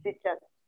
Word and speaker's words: Zichat: Before I call Ibrahim Prosper, I would like to call Zikat Fanda. Zikat Fanda. I don't know if I pronounced that well. Is it Zikat or Zichat? Is Zichat. Please correct me. Yeah, Zichat. Okay, Zichat.